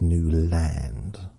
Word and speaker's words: Newland: So I wasn't even pronouncing my name correctly. It's Newland, as we all know.